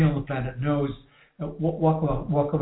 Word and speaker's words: WakaWaka: Yes, waka-waka.